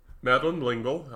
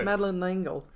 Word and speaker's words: Madeleine L'Engle: And, Madeleine L'Engle, I'm not pronouncing it right. – Actually, no, I think you got it just right. – Okay. – Madeleine L'Engle.